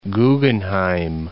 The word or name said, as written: Guggenheim